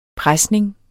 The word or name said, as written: presning